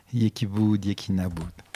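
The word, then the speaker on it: Yekiboud, Yekinaboud (یکی بود یکی نبود): Yekiboud, Yekinaboud.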